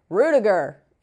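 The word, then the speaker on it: Rüdiger: Rudiger.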